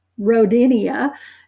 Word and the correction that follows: Rodentia: No, that's not the right pronunciation. Rodentia, go with that one.